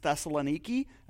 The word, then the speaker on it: Thessaloniki: The modern city is pronounced Thessaloniki.